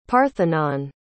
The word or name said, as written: parthenon